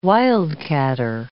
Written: wildcatter

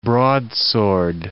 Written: broadsword

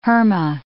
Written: herma

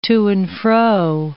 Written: to-and-fro

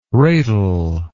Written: ratal

